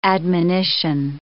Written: admonition

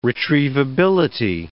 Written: retrievability

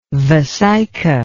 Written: vesica

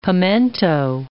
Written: pimento

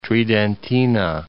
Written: Venezia Tridentina

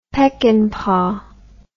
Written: Peckinpah

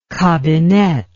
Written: kabinett

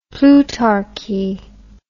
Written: plutarchy

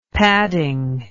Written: padding

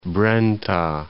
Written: Brenta

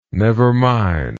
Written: nevermind

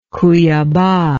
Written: Cuyaba

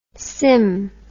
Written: sim

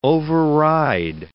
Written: override

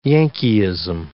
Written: Yankeeism

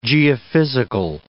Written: geophysical

